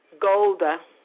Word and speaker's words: Golda: And so, Golan Meir, Golda, I want to pronounce it right, Meir called for Nixon in the middle of the night and begged him to help her country.